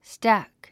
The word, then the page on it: stack: stǽk; スタク